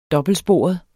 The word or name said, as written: dobbeltsporet